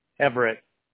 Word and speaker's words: Everett: Everett. – Everett. All right. Am I right in saying it's spelled E-V-E-R-E-T-T? – Yes.